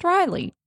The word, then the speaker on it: Raelee: So one word, R-A-E-L-E-E with a capital L in the middle of the word. But I've got little parentheses that say pronounced Riley, which is the only reason I didn't call it Riley.